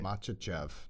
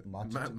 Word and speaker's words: Makhachev: What do you think of uh, Volkanovski and um, Islam Oh yeah, Makhachev. That's going to be an interesting fight. – How do you say his last name? – Makhachev.